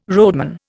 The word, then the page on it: roadman: Indian